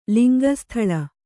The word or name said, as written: ಲಿಂಗಸ್ಥಲ